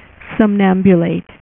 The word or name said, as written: sleepwalker